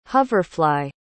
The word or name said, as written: hoverfly